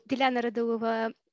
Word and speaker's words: Dilyana Radulova: Yep, so Dilyana Radulova.